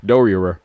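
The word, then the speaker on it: Doerrer: It's a lot of R's. D-O-E-R-R-E-R. – Oh, I don't know how to pronounce that name. – Yes. Dorier. – Dorier.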